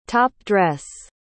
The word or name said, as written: top-dress